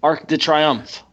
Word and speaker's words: Arc de Triomphe: It's after the Beast Emma conversation where there's the Arc de Triomphe and there's there's bombers dropping bombs. – Arc de what? – Yeah, he does not pronounce French. It's not his. It's fine. – Arc de Triomphe.